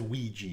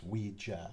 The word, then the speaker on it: Ouija: Because it's like squeegee. It's a Ouija. It cleans the board as it moves.